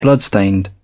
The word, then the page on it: bloodstained: British English